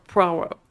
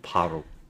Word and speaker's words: Poirot: I knew you were going to. Poirot. He says it with an accent. – Poirot.